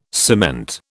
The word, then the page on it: cement: British English